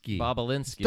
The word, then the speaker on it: Bobulinski: Bobulinski.